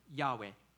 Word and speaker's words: Yahweh: So here God says that his name is Yahweh. The most English Bible translation puts the Lord in all caps here. And instead of transliterating the Hebrew four alphabets, that should be pronounced Yahweh.